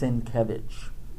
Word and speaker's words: Sienkiewicz: It's pronounced Sienkiewicz, accent on the second syllable.